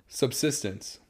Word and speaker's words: subsistence: A lot of them are, like, for them it was a sustenance, you know, it's a substance, right? Am I saying that right? Subsistence. – No. – It's subsistence.